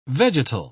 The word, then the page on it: vegetal: British English